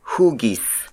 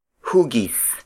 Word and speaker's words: hugis: The Tagalog word for shape is hugis. Hugis. Hugis. Hugis is spelled as H-U-G-I-S.